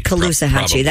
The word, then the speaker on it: Caloosahatchee: I don't know how to say this river's name. – It's Cullahusas Samahibo. – Cullahusas River. – Oh, that's beautiful. – The Cullahusas River? – No, your pronunciation. I think you nailed it. – Oh, authorities recognized. – Caloosahatchee. – That's it. You're exactly right.